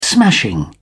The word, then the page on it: smashing: British English